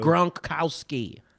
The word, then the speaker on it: Gronkowski: Come on, you can pronounce a Polish name better than that. I know you. – Gronkowski. Yes, there we go.